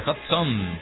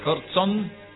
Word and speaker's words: Hodgson: El clásico de las 5, elegido por Carlos Victoriano, Don Victoriano, musicalizando el clásico nuestro de cada tarde hoy, En Peligro, Roger Hodgson. Hodgson no, Hodgson.